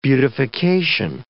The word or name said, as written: beautification